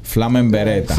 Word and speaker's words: Flama en Beretta: Like how do you pronounce it? – Flama en Beretta.